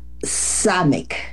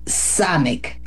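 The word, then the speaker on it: Samek (סמך): Y precisamente, fíjense, la letra número 15 de esta porción que nos toca el día de hoy es la letra Samek, con acento en la A, Samek.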